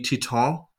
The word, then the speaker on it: Titane: It's Titan. I don't know how you say it. Titan. – It would be sorry, because it's French. It would actually be Titan.